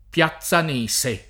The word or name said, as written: Piazzanese